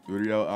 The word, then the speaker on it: Uriel: Uriel Ali.